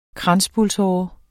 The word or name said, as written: kranspulsåre